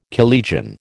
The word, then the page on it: collegian: American English